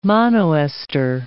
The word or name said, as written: monoester